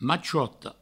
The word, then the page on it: machota: provençal; machouoto